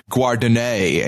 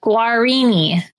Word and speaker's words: Guarini: Now we're going to get tweets where they're like, hey, it's actually pronounced Guardinay, and you're an idiot. – No, Guarini.